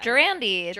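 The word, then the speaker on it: Gerandy: Durandy. – That's how they say it in the audible version.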